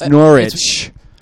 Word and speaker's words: Norwich: We were pronouncing Norwich wrong. It's Norwich. – You don't pronounce the W?